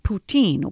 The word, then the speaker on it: poutine: It's spelled P-O-U-T-I-N-E, but it's poutine or poutine is how it's pronounced.